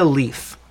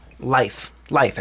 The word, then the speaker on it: Leith: Rest in peace to Virginia Leith. Life. I believe is how you say it. L-E-I-T-H. – Life. L-E-I-T-H. – Yeah, Leith.